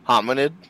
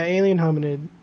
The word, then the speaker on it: Hominid: It was Alien Hamid. – Hominid? – Is that how you pronounce it? – Yeah, alien hominid.